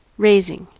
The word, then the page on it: raising: American English